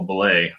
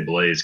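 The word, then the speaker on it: Blais: The guy who came out of nowhere, and I got the name right finally after so many people like found out how to say it today. Samuel Blay. I'll say Blaze because it just sounds better. But Samuel Blay.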